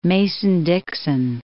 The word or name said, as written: Mason-Dixon Line